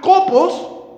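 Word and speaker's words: copos (κόπος): La palabra labor lo puedes marcar porque en griegos se pronuncia copos y esta palabra significa trabajar duro.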